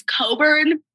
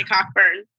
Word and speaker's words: Cockburn: Olivia Wilde is Leslie it's spelled Cockburn, but it's Coburn. I only say Cockburn.